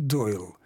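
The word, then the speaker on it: Doyle: Там на конце «и» немое, и это вообще-то по-английски читается «Дойл». Вот это «л», которое звучит на конце, не такое твердое, как в русском языке. Это так называемый среднеевропейский полусмягченный «л».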